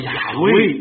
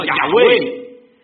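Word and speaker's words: Yahweh: 你去看圣经里面，他讲神叫雅威， YHWH，所以我们发音叫雅威。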